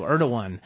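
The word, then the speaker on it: Erdoğan: Let's hope that Erdogan, I always call him Erdogan, you know, but I guess they, Erdogan. You know, heard it. They use that G as like a W, Erdogan.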